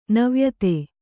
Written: ನವಾಯಿತ

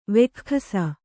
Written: ವೆಖ್ಖಸ

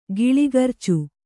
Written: ಕಲಗರ್ಚು

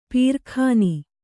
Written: ಪೀರಖಾನಿ

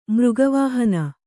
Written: ಮೃಗವಾಹನ

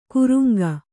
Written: ಕೂರಂಗಿ

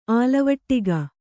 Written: ಆಲವಟ್ಟಿಗೆ

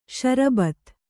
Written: ಸಾರಭೂತ

